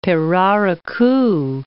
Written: pirarucu

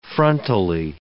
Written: frontally

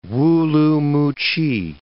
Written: Wu-lu-mu-ch'i

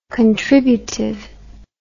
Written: contributive

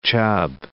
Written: Chubb Crater

